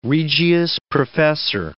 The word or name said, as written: regius professor